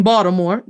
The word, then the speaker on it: Baltimore: No one says Baltimore. In Baltimore, the T is pronounced like a D, in Baltimore.